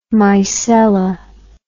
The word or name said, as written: mycella